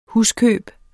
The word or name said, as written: huskøb